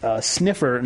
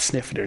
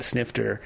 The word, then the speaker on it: snifter: Don said that we said sniffer, not snifter. Now, I'm pretty sure we said snifter. The T might get lost if we say it quickly or something like that.